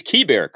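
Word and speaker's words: Keibert: I mean, it's Josiah Gray or the catcher, Kiber Ruiz. It is Kiber, correct? – Kiber, that's what I was told.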